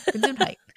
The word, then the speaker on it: Gesundheit: Gesundheit. It's not spelled how it's pronounced.